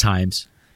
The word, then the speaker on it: Hyams: I had to look it up, but it's pronounced Hyams.